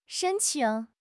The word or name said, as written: shen1 qing3